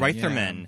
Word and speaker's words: Reitherman: Reitherman.